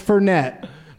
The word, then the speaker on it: Fernet: And he goes, "As I sit there drinking my Fernet Blanca," and it's Fernet is how it's pronounced.